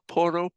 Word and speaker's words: Porro: Pedro Porro. Can you roll your R? Porro. Oh, nice.